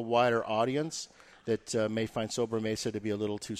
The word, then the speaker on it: Sobremesa: Because this one's pronounced Sobremesa.